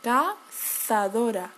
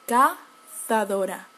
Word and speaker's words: cazadora: Okay, so if you see a z in um, in a word, people from Spain will pronounce it as an angry snake. Okay, cazadora, cazadora. But people from Latin America will pronounce it as um, uh, as Ness, cazadora, cazadora.